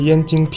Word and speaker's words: 燕京: 我赶紧和阿姨说：“ 是燕京啤酒了，阿姨。 – ” 嗯，燕京啤酒，这个啊又是一个声调的问题了，是吧，约翰？我们在节目里就经常说。汉语里一个字的声调发错，意思就有可能差很多，对吧？